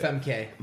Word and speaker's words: Famke: Is that how you say it? Famke? – How do you say it? – Famke. – Famke? – Famke Janssen? – Famke Janssen.